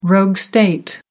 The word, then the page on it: rogue state: American English